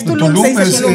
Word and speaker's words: Tulum: Es que realmente no, Tulum. Es Tulum.